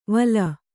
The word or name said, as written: ವಲ